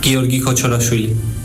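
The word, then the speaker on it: Giorgi Kochorashvili: El último llegar, el georgiano Giorgi Kochorafili, o como se pronuncie. – Mi nombre es Giorgi Kochorafili.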